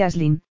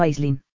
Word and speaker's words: Aisling: Mi nombre no se pronuncia Aislin, sino Aislin.